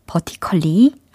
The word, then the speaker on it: vertically: V-E-R-T-I-C-A-L-L-Y. 바로 이 철자인데, 발음을 한번 해보세요. vertically 아니고 vertically. 이렇게 하시면 돼요.